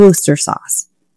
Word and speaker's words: Worcestershire: So I had a few of them just say, just call it Worcester sauce.